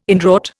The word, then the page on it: inwrought: Indian